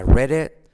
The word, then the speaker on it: read: Mira cómo digo read it. La T casi no se pronuncia. La I como E. I read it. La D de red con, como R suave.